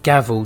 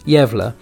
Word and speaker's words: Gävle: Now, in 1966, the town of Gävle in Sweden, now that's spelled like Gavel, G-A-V-L-E, but it's pronounced Gävle, they came up with an idea to set their village apart.